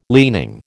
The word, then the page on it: leaning: American English